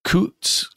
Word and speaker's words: Coutts: David Coutts, I believe is how you say his name. C-O-U-T-T-S.